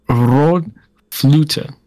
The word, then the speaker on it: Rökflöte: How do you say it? – It is Rod Flute.